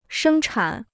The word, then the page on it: 生产: shēng chǎn